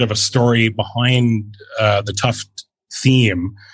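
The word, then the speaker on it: Tufte: Tufty, right? Is that how you would say it? – Edward, named after Edward Tuft. – Tuft. Okay.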